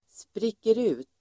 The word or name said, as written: spricker ut